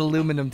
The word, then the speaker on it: aluminium: Bottles, because with the cans, you get that aluminum taste. Or aluminium, as our friends say. – Aluminium is the right way to say it in England.